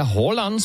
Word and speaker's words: Haaland: Potvrzujete to, co nám tady říkal posluchač v minulé hodině, který říkal, že to je teda Haaland, správná výslovnost Haaland, je to tak?